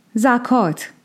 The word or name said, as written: Zakāt